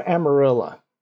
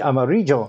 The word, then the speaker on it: Amarillo: And first off, from the fact that I pronounce it that way, that is the correct way to pronounce it. Now, you know this should be amarillo. – Oh, yeah. It's yellow. – But it's no, it's Amarilla.